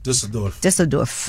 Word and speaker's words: Düsseldorf: Düsseldorf.